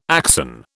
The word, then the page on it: axon: British English